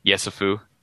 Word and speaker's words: Yesufu: By the way, Joe Yusefu from or am I pronouncing that right? Yesufu. There you go.